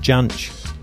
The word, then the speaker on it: Jansch: But also spun out the other day because it was either Stuart McConey or Mark Ratcliffe said that Bert Jansch always liked to be called Bert Jansch. – Oh. – And he pronounced it with the proper J sound.